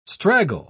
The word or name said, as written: straggle